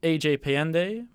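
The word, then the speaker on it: AJPayandeh: You can follow me on Twitter at AJPayende. My last name is spelled P-A-Y-A-N-D-E-H.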